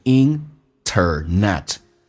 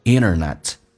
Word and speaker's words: internet: In life without internet，人生当中如果没有，在生活当中没有网络，这边有个单词叫 internet，一般在口语化当中 nt 组合 t 可以不用发音，所以老外可以说成 internet，绝大部分或说成 internet，这个 n 其实是发两次的 inner，剔去掉 net， internet might just be the next step to insanity。